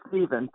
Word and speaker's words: Stephen: Or Steph or Stephen. Or how do you say your name? – It's Stephen, but that's okay.